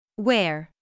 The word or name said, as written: where